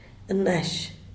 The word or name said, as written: nash